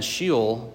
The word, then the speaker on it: Sheol: and it's spelled S-H-E-O-L. I think it's pronounced Sheol.